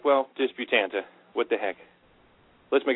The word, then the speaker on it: Disputante: I am proffering that, as far as I can tell, when I say Disputante, everybody, all the locals, agree with me.